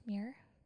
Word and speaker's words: Muir: Muir.